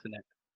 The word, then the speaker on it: Tenet: In this episode, in anticipation of the release of Tenet, we are discussing Tenet. – Yeah, Tenet. – Not Tenet. – Okay, all right. – Not one syllable.